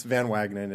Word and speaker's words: Van Wagenen: So it's Van Wagenen. It's just like when you see it and you say it, it's exactly like it's spelled, but it's a long Dutch name.